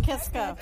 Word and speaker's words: Kiska: It's like Kiska, am I saying that right? – Yes, like Kiska.